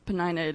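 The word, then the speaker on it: Peninnah: We think Sherb and I decided that's how we pronounce her name, so Penina it is.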